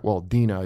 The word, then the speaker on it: Dinah: Dina, it turns out, is how you say it in Jew talk. I found out from my girlfriend. But Dinah, I knew her as.